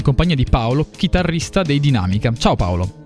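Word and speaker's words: Dynamica: Ok, allora, per prima cosa puntualizzo solo una cosa sul nome, nel senso che sì, si pronuncia Dinamica, ma la prima è una Y, quindi D-Y-Namica, perché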